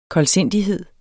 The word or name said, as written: koldsindighed